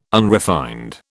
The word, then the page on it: unrefined: British English